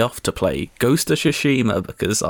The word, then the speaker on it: Tsushima: I think you'll find that it's pronounced Tsushima.